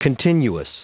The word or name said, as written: continuous